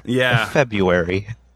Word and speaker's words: February: There's an R after the B. How can you pronounce that? – It's easy. You don't. You just say February. – Yeah. February. Okay, just pretend there's no R.